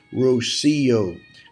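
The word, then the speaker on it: Rocío: For my friends listening in on our prayer time, her name is pronounced Rocio, from the emphasis on the sea, as in the town in Spain and the Spanish translation of Psalm 110, verse 3, of the dew of the morning.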